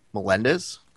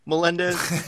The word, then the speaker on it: Melendez: You know, Ronda was was popular, but nowhere near as popular as uh, their interactions with Gilbert Melendez. Really? Oh my God! I'm so sorry. I, I said his name wrong. Uh, Melendez.